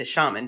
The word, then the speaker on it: shaman: And thank you so much for correcting my pronunciation of shaman into shaman, because of course I knew that, but I looked at the word and I froze. – You could say either is fine.